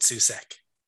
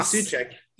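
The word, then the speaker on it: Souček: I'll go with Susek. Or Suchek. Whoever else you pronounce his name. – I'm talking Suchek. – Yeah. – Is the right answer.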